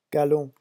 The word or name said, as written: galon